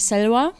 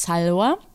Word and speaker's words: Salwa: Sal, sagt man Salwa oder Salva? Äh, man kann es verschieden aussprechen. Es ist eigentlich Selwa. Ähm, ich stelle mich immer vor als Salwa mhm.